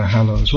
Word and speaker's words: Hallows: We were just seeing in the bookstore, they're advertising Harry Potter and the something, new book, Hallows something, whatever, Hallows. What does Hallows mean? Anyone knows? Sound rhymes with gallows.